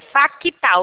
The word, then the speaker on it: FACTA: Libera o FACTA para mim e o Banco Master. Por favor. É FACTA, o C é mudo.